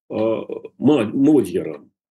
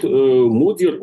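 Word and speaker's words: Magyar: То же самое с этим самым Модьяром. по-венгерски все-таки там через «о» произносится на первую букву гласное ударение. Так вот, Модер, он не бог весь какой демократ, он, конечно, тоже такой националист и все прочее, но он городской националист.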